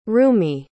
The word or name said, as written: rumi